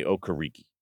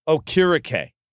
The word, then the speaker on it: Okereke: Now, I want to say Okereke, but I read a story that it's not pronounced Okereke. He used to pronounce it Okereke, but then he corrected everyone, and it's Okereke. So a funny story, the story I read was actually that he called himself Okereke. You know, that's how he pronounced his own last name. So on Monday Night Football, I believe it was last year or Sunday night, whatever it was, when the player introduces himself, he says Okereke.